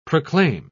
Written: proclaim

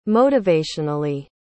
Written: motivationally

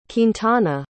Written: quintana

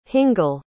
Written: hingle

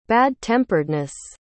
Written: bad-temperedness

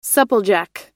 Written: supplejack

